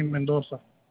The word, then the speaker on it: Mendoza: Johnny Mendoza. – Johnny. Spell that last name for me. – M-E-N-D-O-Z-A.